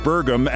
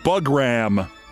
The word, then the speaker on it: Burgum: On top of that, he mispronounced the name of the North Dakota governor and GOP wannabe Doug Burgum as Doug Bugram.